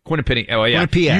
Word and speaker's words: Quinnipiac: Quinnipiac? How do you pronounce that? – Quinnipiac. – You say it.